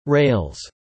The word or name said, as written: rails